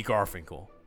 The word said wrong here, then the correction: Gorfinkel: Gorfinkel, sorry, I butchered that. Jordan B. Gorfinkel.